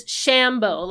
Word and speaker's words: Shambo: And I'm like, it's not Shambo. It's Shambo. Like, emphasis, like Rambo. It's not Rambo.